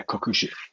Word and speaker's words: Kokushi: One is Kokushi. Am I saying that correctly? Yeah, Kokushi. Kokushi Budo Institute.